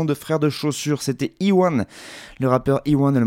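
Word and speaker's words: E.One: Un album entièrement écrit et réalisé par lui-même, Iwan donc, euh, ça s'écrit E majuscule point one comme un en anglais, hein, pour tous ceux qu'on fait anglais euh, première langue.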